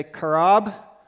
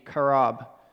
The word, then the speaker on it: qarab: Okay, and so if you want to like spell this out phonetically, it's like Q-A-R-A-B, karab. Okay. Like kind of like Qatar or Qatar, only it's karab, right? It's a Q word.